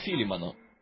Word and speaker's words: Филимон: Итак, Филимон, кто-то там сзади сказал, и мне кажется, таково и есть правильное его произношение.